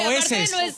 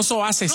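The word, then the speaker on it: soeces: Bueno, soeses. – ¿Cómo se dice? – Soeses, no soases.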